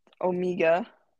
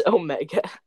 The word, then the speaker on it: Omega: They wanted Omega. Did I say it wrong? – It's Omega.